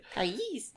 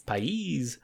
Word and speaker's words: Pais: Josh Pais, I guess that's how you say it. – Pais. – Pais.